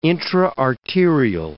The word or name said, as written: intra-arterial